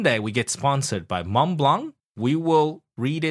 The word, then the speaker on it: Montblanc: Montblanc. Okay, you don't pronounce the T and the C.